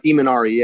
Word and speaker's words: Imanari: When you did your arm, I always pronounce it wrong. I pronounce your role, the role, the Arminari. – Oh, the Imanari, yeah. It's named after the Japanese guy.